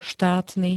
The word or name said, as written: štátny